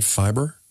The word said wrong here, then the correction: Fybe: Five. – Sorry. – F-Y-B-E. – F-Y-B-E. Five.